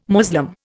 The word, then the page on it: Moslem: Indian